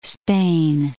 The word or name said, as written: Spain